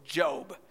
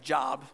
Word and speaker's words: Job: Now, as you read the book of Job, it looks like Job, J-O-B, but it's spelled Job.